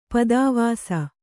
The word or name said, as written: ಪದಾವಾಸ